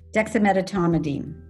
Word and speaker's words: dexamethasone: Dexamethasone.